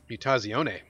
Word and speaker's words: Mutazione: Interesting that Mutazione, am I saying that right? – Mutazione.